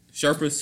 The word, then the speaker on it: Sherpas: Sherpas.